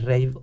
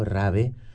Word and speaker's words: rave: hablando de las fiestas rave o rave, como dicen en España, que son enérgicos con la pronunciación en español de todas las palabras.